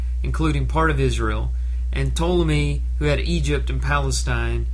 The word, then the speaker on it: Ptolemy: By the way, Ptolemy is spelled P-T-O-L-E-M-Y.